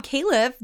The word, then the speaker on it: Califf: Speaking of other big biotech news of the week, Rob Califf, the FDA commissioner nominee, had his Senate confirmation hearing. I think one of the biggest takeaways is Meg, you pronounced his name right.